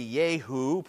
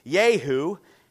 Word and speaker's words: Jehu: It's J-E-H-U, so maybe Yehu, probably in Hebrew. It almost sounds like a Yahoo. He wasn't a Yahoo. Yehu, and how the Israelites did this very thing when he was anointed as king.